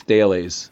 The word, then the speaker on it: Thales: His name is Thales of Miletus, and it's spelled like Thales, T-H-A-L-E-S, but it's pronounced Thales.